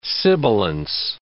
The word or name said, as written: sibilance